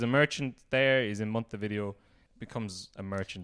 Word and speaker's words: Montevideo: So Spanish speakers would pronounce the V as a B. – Oh. – So they say Montevideo.